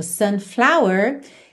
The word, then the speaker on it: sunflower: Por exemplo, sunflower teria flower, a sílaba tônica no fla, fla.